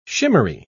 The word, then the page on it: shimmery: British English